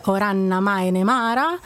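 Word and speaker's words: Òran na Maighdinn-mara: La pronuncia in gaelico è difficile, Oranna Maenemara, spero di averla detto giusta.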